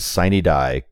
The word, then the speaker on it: sine die: For those who don't know, the Latin phrase sine die, correctly pronounced sine die, means without day.